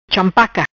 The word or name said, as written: Champaca